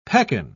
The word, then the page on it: pekan: British English